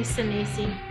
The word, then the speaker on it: Sinise: I think you need to pronounce his name correctly if you're going to have him on. – Gary Sinise. – It's Gary Sinise.